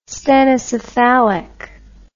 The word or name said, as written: stenocephalic